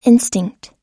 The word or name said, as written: instinct